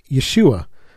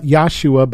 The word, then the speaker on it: Yeshua: abbreviated to the name Yeshua, not Yahshua, by the way, or some other pronunciation. It's Yeshua, I'll address this in another teaching.